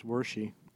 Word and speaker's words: Wershe: Wersch or Wershey? – It's Wershey.